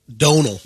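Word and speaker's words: Domhnall: Dominal? – And I would say... – hold on. – It's pronounced Donal. – Donal Gleason. In his accent. Dumnol is what I've always heard.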